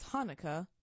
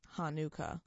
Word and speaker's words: Hanukkah: Hanukkah is pronounced as Hanukkah, not Hanukkah.